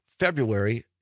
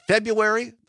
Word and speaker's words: February: Is it February, the way everybody says it, or is it February with the R in it? Like most Wisconsinites say February. The R is silent.